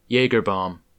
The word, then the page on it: Jägerbomb: American English; Midwestern accent